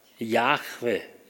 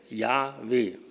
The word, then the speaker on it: Yahweh: Yahweh, Yahweh, so wird das ausgedrückt dort.